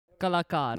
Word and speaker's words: Kalakar: So for those of you who don't know, but you probably know, but Paramesh Verma, Mr. Paramesh Verma, Punjabi singer, Kaligar. Is that how you say it? Kaligar? – Kalakar.